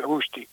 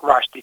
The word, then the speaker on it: Rushdie: però io soprattutto continuerei a ricordare Salman Rushdie, o Rushdie, tutte e due le pronunce vanno bene, una all'indiana e l'altra all'inglese.